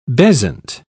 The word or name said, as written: bezant